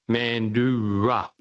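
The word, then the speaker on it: Mandurah: It's an indigenous name, and it's pronounced Mandura. – Yeah, Mandara.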